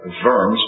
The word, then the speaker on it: Worms: Eventually, he was brought to trial at what was called a diet, and if you've ever read about the diet of worms, cheer up, it's actually the diet of worms, pronounced with a V, not the diet of worms.